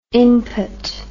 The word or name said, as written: impot